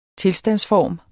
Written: TILSTANDSFORMERNES

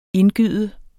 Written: indgyde

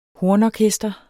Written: HORNORKESTRE

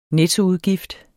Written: nettoudgift

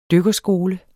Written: DYKKERSKOLE

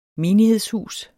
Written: menighedshus

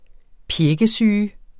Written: pjækkesyge

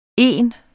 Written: ene

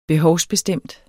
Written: behovsbestemt

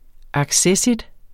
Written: accessit

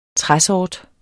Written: træsort

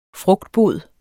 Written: frugtbod

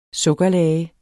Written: sukkerlage

